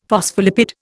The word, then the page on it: phospholipid: Indian